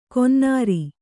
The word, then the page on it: ಕೊನ್ನಾರಿ: konnāri